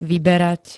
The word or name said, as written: vyberať